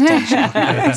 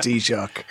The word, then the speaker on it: Taoiseach: It's really like the shock you get from a good cup of tea. It's just Taoiseach. It's just Taoiseach.